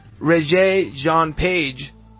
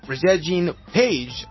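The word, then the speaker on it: Regé-Jean Page: Regé, let me pronounce this again well. Regé Jean Page, I think it's pronounced, is not part of the main crew.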